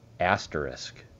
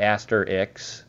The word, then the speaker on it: asterisk: and that word is, ready? Asterisk. Yeah. Not asterix. There's a lot of ways to mispronounce that word, but it is I admit it, it's a challenge.